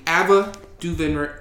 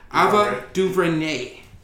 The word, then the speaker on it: Ava DuVernay: It's going to be Ava DuVernay. I can't even say her name. Ava DuVernay.